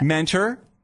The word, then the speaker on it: Mentor: But how would you pronounce it? – Mentor. – Like hard T.